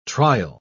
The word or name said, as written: trial